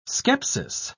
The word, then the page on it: scepsis: British English